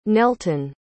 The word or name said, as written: nelton